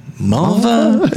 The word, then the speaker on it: Mulva: But you can't say it like that. You gotta say Mulva.